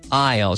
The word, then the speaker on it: aisle: Isle. Se escribe Aisle. It's pronounced Isle, like I'll be there. I'll come with you.